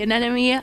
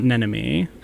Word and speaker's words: anemone: So our character is named Anemone, as in like sea anemone. – It's Anemone, not Nenemy. You pronounced it wrong.